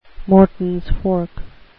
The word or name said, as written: mortons fork